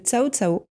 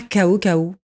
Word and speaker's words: Cao Cao: pourquoi Cao Cao et non pas KO KO alors parce qu'en fait, Cao Cao a une signification, c'est une personnalité mythique en Chine qui, lorsqu'on l'appelle, il arrive. Donc c'est pour ça qu'on tient à la prononciation de Cao Cao, parce que ça a une signification.